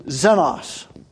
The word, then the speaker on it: xenos: The word that's probably most often translated stranger or strangers in the New Testament is the Greek word xenos. That'd be like X-E-N-O-S if we were doing it. The X pronounced like a Z if we were doing it in the English language.